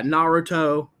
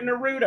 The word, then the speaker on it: Naruto: All right, we got Naruto. – Thank you for pronouncing it right, by the way. – Because if you had been like Naruto, I was gonna get up and leave.